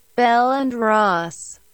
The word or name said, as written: Bell and Ross